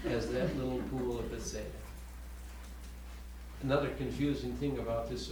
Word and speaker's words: Bethesda: Bethesda. – Yeah, Bethesda.